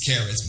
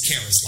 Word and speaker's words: Charis: Now, it's really pronounced Charis, but I got folks in Mississippi that would jack it up, so we call her Charis. But the proper pronunciation is Charis, like Christopher or Christmas.